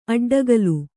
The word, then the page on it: ಅಡ್ಡಗೆಲ್ಲು: Kannada